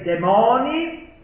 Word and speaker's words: demoni: Ho utilizzato la penna per, scrive Montaigne, lenire l'angoscia, ammansire i demoni, guardate l'accento sta sulla O, rimuovere gli incubi.